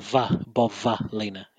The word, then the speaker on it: Bovalina: I realize it's got a V in it. – Bovalina. – Bovalina. But in an Aussie accent, it sounds like Bobalina.